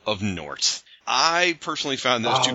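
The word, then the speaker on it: Gnort: I asked J.M. DeMatteis how to say Nort. He says the G is silent.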